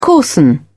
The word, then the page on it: coarsen: British English